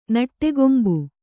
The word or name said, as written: ನಟ್ಟಗೊಂಬು